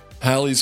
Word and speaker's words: Halley: Halley's Comet. And that is how it's pronounced. I know, it's weird, but that is how it's pronounced. And I'm going with it.